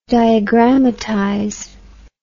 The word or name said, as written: diagrammatize